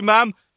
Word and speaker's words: ma'am: God bless you, ma'am. If I don't get something in the honours list, then there's something wrong. – It should rhyme with jam, just in case people are wondering.